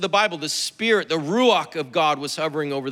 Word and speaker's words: ruach: And in the Old Testament, the word for spirit in Hebrew is ruach. You gotta kind of say it like got something stuck in the back of your throat.